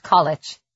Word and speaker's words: Colwich: She knew the town, which the locals pronounced College.